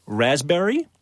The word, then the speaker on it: raspberry: K-N-E-E-L, Neil. – Raspberry, answer. – Silent right. letters. – Yes, silent letters. Oh, yeah. Raspberry is... – the silent S... – P. – Well, P. Sorry. – Silent P, right?